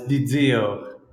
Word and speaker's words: Dizio: Uh, it's Dizio, but unless you're Italian, it's hard to pronounce.